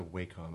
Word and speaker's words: Wacom: I think it's a Wacom. I think that's supposed to be the industry true pronunciation of the brand.